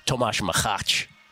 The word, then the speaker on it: Machač: Yesterday, Tomáš Machač is actually how I pronounce it.